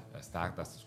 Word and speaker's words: Stardust: soprattutto lo dice anche lui stesso rispetto a Ziggy Dardust, Stardust, scusate, continuo a pronunciarlo male,